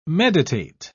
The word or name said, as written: meditate